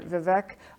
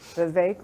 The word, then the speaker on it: Vivek: Vivek, actually, as he said during one debate, Vivek rhymes with cake.